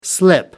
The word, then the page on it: slip-up: American English